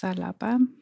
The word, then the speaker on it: Xalapa: talking about being in Xalapa. Did I say that right? – I want to say it's Xalapa. – Xalapa.